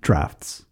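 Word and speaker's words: draughts: They had droughts. So that's checkers. They called it droughts. – Drafts. – Jots. – Drafts. – Drafts? – Drafts. – Okay. – Like a draft beer. – It's spelled D-R-A-U-G-H-T? – Drafts.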